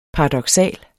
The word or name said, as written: paradoksal